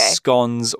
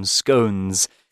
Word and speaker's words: scone: How you pronounce this word probably depends on where you come from in the UK. There is a big debate whether it's pronounced scone or scone. You can say whichever you like.